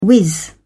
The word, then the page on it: whizz: British English